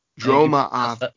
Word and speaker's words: Dromothra: Like Dromothra. – Yeah. – Ma is a separate thing, and then Athra.